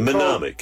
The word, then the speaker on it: mnemonic: What is it mnemonic. – Called? – Mnemonic. – Mnemonic. Phenomenic? – Mnemonic. – It's MN? Is it MNE? – Yeah, that's how you say it. Yeah, it's an MN. – Mnemonic.